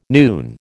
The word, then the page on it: noon: American English